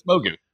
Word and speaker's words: Mogu: What is it? It's Mogu. – Mogu. It's, Mogu. it's just Mogu.